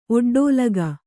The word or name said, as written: ಒಡ್ಡೋಲಗ